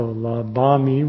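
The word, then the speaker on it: labhāmi: So labami with a long A, and then labasi, labati, labama, labata, and labanti.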